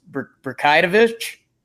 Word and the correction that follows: Brazdeikis: The way I've learned it is if you just look at the name really closely, it's kind of pronounced how you'd think it's supposed to be pronounced. You just kind of take it slow. It's Brazdeikis.